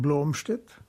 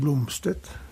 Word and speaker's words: Blomstedt: Es kommt darauf an, wo ich mich befinde. In Deutschland allgemein Blomstedt, in Skandinavien Blomstedt und in Amerika Blomstedt.